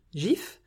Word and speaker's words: GIF: Tout comme le dictionnaire anglais Oxford qui accepte les deux prononciations, mais, et je cite, à tort, c'est un G doux, prononcé GIF.